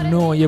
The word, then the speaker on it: Neu: Neu Radio si scrive Neu, si pronuncia Noi e vuol dire nuova, nuova emittente urbana.